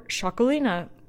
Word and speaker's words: Txakolina: It's Shaquely or Shaquelina, which is spelled, maddeningly enough, TXAKOLI or TXAKOLINA, if you're saying Shaquelina.